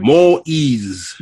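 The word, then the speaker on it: Moïse: Moise, am I pronouncing his name? – Moise.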